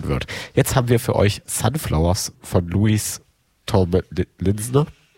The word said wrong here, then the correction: Louis Tomlinson: Linson? Wie wird es ausgesprochen? Kann mir jemand helfen? – Sunflowers von Louis Tomlinson.